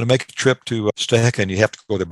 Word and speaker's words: Stehekin: called Stuhekin, and it's spelled S-T-E-H-E-K-I-N, but pronounced Stuhekin.